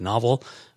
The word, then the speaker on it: N0V3L: It was called Novel, but it was spelt like an actual like book, like novel. But they spell their name, like the band name, even though it's pronounced Novel, N0V3L. Yeah, stylized.